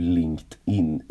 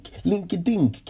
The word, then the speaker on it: LinkedIn: Det tog mig väldigt lång tid innan jag fattade hur LinkedIn uttalades. Jag kallade Linking... Nej. Linking... Nej, vad kallar jag det?